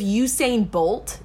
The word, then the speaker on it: Usain Bolt: Like, if Usain Bolt, did I just say that right? – Yeah. – Usain Bolt became a zombie, he would be slow? – Then we're all dying.